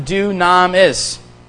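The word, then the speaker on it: dunamis (δύναμις): D-O-N-A-M-I-S. And you pronounce it dunamis. D-O-O-N-A-M-I-S.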